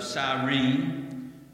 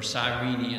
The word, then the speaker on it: Cyrene: Lucius of Cyrene, or Cyrene as some pronounce this.